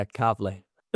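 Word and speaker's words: Calverley: And the first L is silent. – Yeah? – Yeah, Calvary. – Okay, I'm lost. Do you want to explain to me? – I don't know. Calvary, we don't pronounce the L and it's an R instead.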